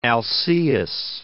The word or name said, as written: Alcaeus